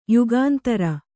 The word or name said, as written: ಯುಗಂಧರ